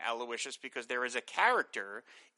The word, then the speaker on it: Aloysius: Jay Effingham Bellwether says, the name Aloysius is pronounced Aloysius. It is the Latinized version of names like Louis, Louis, Luis, Luigi, etc.